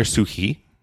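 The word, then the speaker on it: Suchý: which is a game by Vladimir Sukhi is how you pronounce it.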